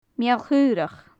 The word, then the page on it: mial-chaorach: gaelic